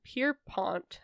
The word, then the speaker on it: Pierpont: So JP Morgan, if you didn't know, is John Pierpont, I think is how you say his middle name, Morgan.